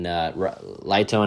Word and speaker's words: Raito: And then Lito and I sprinted. His name is spelled R-A-I-T-O, but I believe it's Lito is how he said it in Indy when we talked to each other, the Japanese rider.